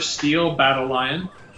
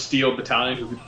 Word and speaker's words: Steel Battalion: And the candidates were Steel Battle Lion, also known as Steel Battalion, for people who can actually pronounce things correctly.